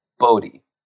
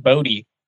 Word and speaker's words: Boutte: And then Kayshawn, I think we're calling him Boutte, but I actually prepared on this. I heard him, I think he pronounces it Boutte.